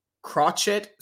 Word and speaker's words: crochet: The new Catwoman should be an obese school bus driver that wears crochet sweaters, who is crocheted sweaters. – See, that's why you got to have a white man on here. Crochet is how it's pronounced.